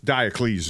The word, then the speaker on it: Diocles: How you say his name again? – Just Diocles is good enough. – Diocles. Okay.